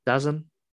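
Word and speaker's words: DAZN: You can watch that on, how do you say their name? Dazon. – Yeah.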